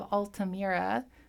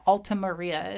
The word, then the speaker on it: Altamira: So this is The Caves of Altamira. That's a really weird word for me to I keep like trying... It's like Altamaria is what I keep trying to pronounce it as.